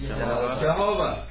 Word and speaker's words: Jehovah: Yahweh and Jehovah. – Two possible pronunciations. You will see Jehovah with a J, and you will see Jehovah with a Y. It is a Y, but it has a J sound.